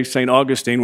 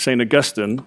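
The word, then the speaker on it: Saint Augustine: And we say St. Augustine where we go to eat dinner, but it's St. Augustine. If you're in academia, that's how they say his name.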